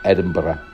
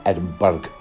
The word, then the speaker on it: Edinburgh: Oh, that's pronounced Edinburgh. Do you get it? Edinburgh. Edinburgh, not Edinburgh.